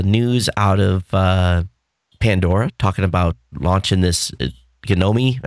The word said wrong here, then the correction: Genome: Is that the right way to pronounce that? The Gnomi project? Or did you read about that? – Oh, the genome. – Genome. Project. There we go.